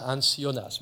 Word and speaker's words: Hans Jonas: C'est qu'il existe un autre Jonas, Bon, en allemand, Hans Jonas se prononce Hans Jonas, mais peu importe.